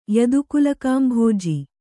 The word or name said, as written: ಯದುಕುಲಕಾಂಭೋಜಿ